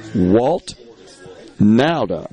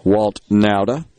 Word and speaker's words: Walt Nauta: Walt Nauta, I believe, is how it is pronounced. It's spelled N A U T A.